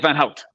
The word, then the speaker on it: Van Houdt: I'm probably pronouncing your last name wrong, right? – Mike Van Houdt. Close. Houdt. It's all good.